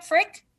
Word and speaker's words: Frick: Did I say that right, Frick? – You did.